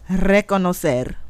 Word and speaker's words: reconocer: You got to roll your R's with this one, okay? So, the word of the day is reconocer. – Reconocer.